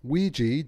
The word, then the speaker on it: Weegee: So Ouija, which is O-U-I-G-A for the Ouija board, he took that phonetically and recast it as Ouija, W-E-E-G-E-E, and that's the name he used for the rest of his career.